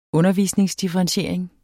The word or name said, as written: undervisningsdifferentiering